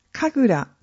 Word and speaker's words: 神楽: 里神楽の魅力はどんなところ？神様と人間が共に楽しむところが一番の魅力です。里神楽は神聖でかしこまったものというイメージを持つ人もいると思います。しかし、神に楽しむと書いて神楽と読むように、人間も神様も一緒に楽しむ芸能なのです。